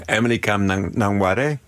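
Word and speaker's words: Emily Kame Kngwarreye: Emily Kame Nwari. – Emily Kame Nwari.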